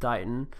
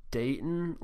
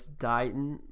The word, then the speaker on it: Dighton: I believe it's pronounced Dighton. I think I pronounced it Dayton last time, so it's Dighton, like D-I-G-H-E-N, but it's spelled like bright with a D and then on.